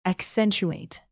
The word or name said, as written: accentuate